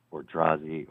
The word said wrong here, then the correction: Odorizzi: or however you pronounce it. – Yeah, Odorizzi. Odorizzi, absolutely, that'd be great.